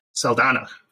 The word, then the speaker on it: Saldana: Saldana.